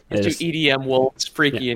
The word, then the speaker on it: Freki: Maybe Freki. – Who are these guys? – Maybe Freki, honestly. So Freki is spelled F-R-E-K-I, could easily be Freki, and Gary is spelled G-E-R-I.